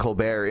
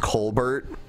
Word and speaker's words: Colbert: I remember Bill O'Reilly talking to Stephen Colbert, interviewing him on the O'Reilly Factor, and found out that his name was originally pronounced Colbert, which is like an Irish name, but instead he pronounces it Colbert, and he's like, why'd you choose a French name?